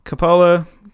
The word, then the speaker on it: Coppola: Coppola? Coppola? – Coppola.